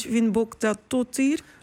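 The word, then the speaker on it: Finnbogadóttir: Vigdis Finnbogadottir, zeg ik dat goed? – Dochter. – Dochter. – Ja, dubbel T is echt.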